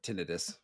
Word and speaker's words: tinnitus: Or tinnitus, that's the way you pronounced it correctly.